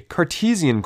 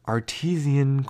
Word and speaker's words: Cartesian: So I like to think of the Cartesian coordinate system, which I always used to mispronounce as Artesian coordinate system.